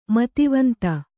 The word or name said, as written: ಮೇಧಾವಂತ